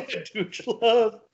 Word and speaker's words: DUGELUV: But it's spelled like Dooge Love. – Yeah, Dooge Love. – Dooge Love. Oh. D-U-G-E-L-U-V. Dooge Love.